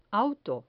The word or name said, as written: auto